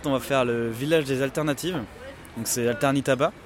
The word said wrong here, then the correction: Alternatiba: Alternatiba. Attends, je le prononce mal.